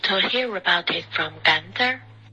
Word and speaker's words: Gunther: Gunther，刚刚的刚。